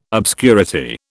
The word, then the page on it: obscurity: British English